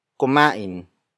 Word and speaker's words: kumain: It works as an adverb since it illustrates the action in the sentence, which is kumain. Kumain. Kumain. Kumain is spelled as K-U-M-A-I-N.